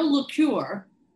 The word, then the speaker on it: L'Ecuyer: So my friend Dale LeCure, did I say it right, Dale? – You did it.